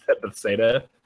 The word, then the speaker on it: Bethesda: How do you say Bethesda for some reason? – Bethesda. – Yeah, that's how dyslexics say it.